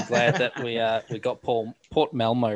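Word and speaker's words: Malmö: I hope I'm saying it right. That's the It is Malmo. They hit us, hit us up after the Festival Football podcast saying we nailed it.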